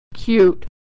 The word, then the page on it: cute: y